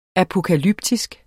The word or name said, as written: apokalyptisk